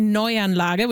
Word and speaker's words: Neuanlage: In German, I would say Neuanlage, which means new settlement.